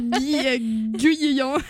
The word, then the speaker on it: Guyon: Là, hey, ce matin, la surveillante, Guillon. – C'est ça, parce que, tu sais, mon nom de famille, c'est Guillon, mais ça s'écrit G-U-Y-O-N, comme Guy, qu'on rajoute un on à côté, Guillon.